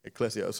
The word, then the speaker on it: ekklesia: You know, that's how you pronounce it, ekklesia. Some pronounce it ekklesia.